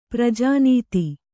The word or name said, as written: ಪ್ರಜಾನೀತಿ